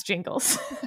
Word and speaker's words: Gingles: This is the difference between someone who listens to the Supreme Court arguments versus someone who reads the transcripts, is I know how to pronounce jingles.